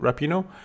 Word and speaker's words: Rapinoe: So when asked after the game about her favorite memory of international soccer after the World Cup, Megan Rapinoe, that's how we say her name, Rapinoe, said equal pay.